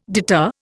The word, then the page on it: deter: Indian